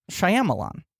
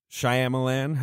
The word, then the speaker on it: Shyamalan: His name is not pronounced Shyamalan. – It's Shyamalan. – It's like Shyamalan.